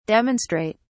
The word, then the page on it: demonstrate: British English